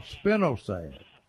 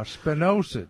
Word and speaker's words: spinosad: products on the market with an ingredient called spinosad, or spinosad. It's spelled S-P-I-N-O-S-A-D.